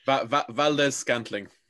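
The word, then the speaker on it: Valdes-Scantling: And then in overtime, a fumble by, I'll try to pronounce it, Scandrick? – Valdez Scantling. – There we go.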